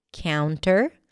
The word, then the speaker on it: counter: And then we have the word counter, cow, count, counter. But you can actually drop the T here and just say counter.